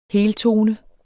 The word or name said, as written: heltone